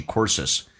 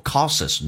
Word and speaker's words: courses: A teacher was trying to make use of her psychology courses. Psychology, 心理学，你看一下，重音是在 K 上。 Psychology, psychology, psychology courses. 这个单词不要念作 causes, no, courses, 嘴巴收得很圆，重度卷舌。